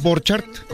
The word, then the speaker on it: Borchart: Borchart, como se escribe, como tal cual.